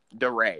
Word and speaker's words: DeRay: Like, uh, what's the kid D Ray with the blue vest or whatever? – DeRay. – Yeah, that's how you say his name.